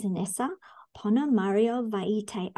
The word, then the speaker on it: Inesa Ponomariovaite: Today's guest is Inesa Ponomariovaite.